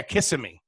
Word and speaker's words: Kissimmee: Kissimmee. – And it's Kissimmee.